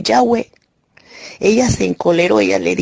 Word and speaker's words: Yahweh: Los que esperan en Jehová, o los que esperan en Yahweh. Yo por lo regular utilizo la pronunciación Yahweh. No me gusta la pronunciación Jehová que usa la Reina Valera, porque sé que es bastante incorrecta.